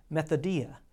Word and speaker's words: methodia: You know, the actual Greek word that is translated into schemes is a word that you may even recognize as it is pronounced methodia, which is the word that we get our English word method from.